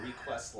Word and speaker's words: request: Total Request Live? – It's live. It's live, and I think it's because you're putting the wrong emphasis on the wrong syllable. It's request.